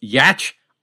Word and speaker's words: Giertz: Her name is Simone and spelled G-I-E-R-T-Z, which, of course, is pronounced Yatch. How can that be? Because she's Swedish.